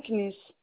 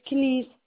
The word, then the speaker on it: Kniss: C'est Madame Kniss. – Pouvez-vous me dire K-N-I-S. – comment s'écrit votre nom, alors, madame? – K-N-I-S Kniss.